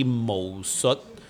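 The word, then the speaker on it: Mo Sut (武術): That same character with Cantonese pronunciation would be Mo. Okay. So it's actually Mo Sut, martial art in Cantonese.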